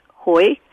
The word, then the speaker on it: hui: It's spelled H-U-I, and it's pronounced hoi, mm-hmm. And it's a tonal language, so my Vietnamese friends to this day look at me blankly when I try to say it. But it roughly rhymes with the word boy.